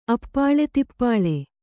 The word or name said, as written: ಅಪ್ಪಾಲೆತಿಪ್ಪಾಲೆ